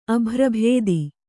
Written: ಅಭ್ರಭೇದಿ

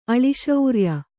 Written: ಅಳಿಶೌರ್ಯ

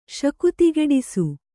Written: ಶಕ್ತಿಗೆಡಿಸು

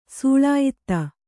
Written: ಸೂಳಾಯಿತ್ತ

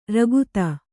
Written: ರಿಕ್ಥ

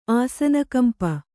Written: ಆಸನಕಂಪ

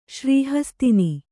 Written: ಶ್ರೀಹಸ್ತಿನಿ

